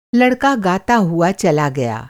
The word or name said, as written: गाना